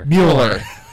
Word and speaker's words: Mueller: Say it again. – Mueller. – Just like Bueller.